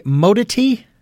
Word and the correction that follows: modernity: I don't think I have that pronounced correctly. But it's also in the introduction. – Oh, modernity. – Thank you, modernity. – Yes, modernity.